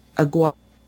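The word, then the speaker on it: Aguirre: I read a review of this book called Grim Space by Anne Aguas, that's how you say it, on, what is it, the SF Signal, or SF Site, I can't remember.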